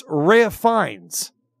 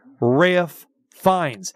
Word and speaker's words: Ralph Fiennes: It's Rhea Fiennes. He spells his name like Ralph. It's Ralph Fiennes. Okay. But his name is pronounced Rhea Fiennes.